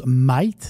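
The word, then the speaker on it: Mayht: Ben, comme on m'a dit, je, le prononce Might.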